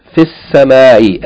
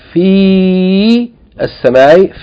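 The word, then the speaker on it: fis-samai (في السماء): As-samai, seen, it begins with seen. Seen is a sun letter, so the seen is doubled, and the lam is not pronounced. And the fi is shortened because it's followed by sukun. Not fi as-samai, fis-samai.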